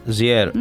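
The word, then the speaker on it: JR: Πώς τον προφέρεις τον καλλιτέχνη? Ζιέρ. Ναι.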